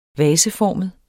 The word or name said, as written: vaseformet